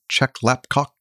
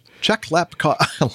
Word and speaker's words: Chek Lap Kok: 174 passengers and 13 crew aborted their takeoff roll at Hong Kong Chek Lap Kok International Airport.